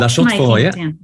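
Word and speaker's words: La Chaux-de-Fonds: Now, another site that's on in the French speaking part is Le Chaux de Fonds. Have I pronounced that right? The watchmaking. – La Chaux de Fonds, yeah.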